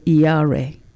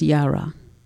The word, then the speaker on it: yare: In the Old Testament, there is a word, Yare, and actually it's pronounced Yara.